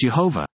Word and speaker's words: Jehovah: The Tetragrammaton, also called the four-letter name of God, IV, is spelled with only four consonants, Yud, He, Vav, He, often pronounced Jehovah. Notice the use of Y, as there is no J sound in Hebrew.